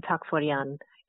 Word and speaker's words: Takforyan: Hello, my name is Nadine Takforyan, and I'm pronouncing that with an Armenian accent.